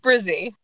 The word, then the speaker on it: Bresee: How do you pronounce your last name? – It's Brizzy. No one pronounces it right.